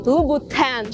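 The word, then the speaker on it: Louboutin: Во французском языке сочетание букв О и У обозначает звук У, поэтому никакого звука А там быть не может. Поэтому правильно произносить название этого бренда Кристиан Лубутен, Лубутен через У.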